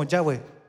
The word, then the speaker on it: Yahweh: se decía como Yahweh. Pero ellos dicen que el ser humano lo pronuncia sin, eh, digamos, sin, sin hablarlo, con la respiración. Y cuando uno inhala, pronuncia como el ya. Y como. Y cuando vota es como we.